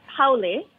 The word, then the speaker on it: Haole: So you're a Hapa Haole. Did I pronounce that right? I'm not sure. – Close. Okay. Haole.